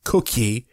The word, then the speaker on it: koekje: And it says also the Dutch language from around 1703 had adopted the word cookie, which is spelled K-O-E-K-J-E, cookie, which means little cake, to have a similar meaning for a similar hard baked product.